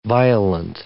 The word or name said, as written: violent